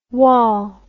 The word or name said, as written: waul